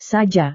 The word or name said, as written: saja